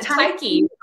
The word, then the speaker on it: Tyche: Yeah, Tyche.